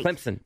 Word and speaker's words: Clemson: Clemson. Say it right. – Clemson. – Put a P in there.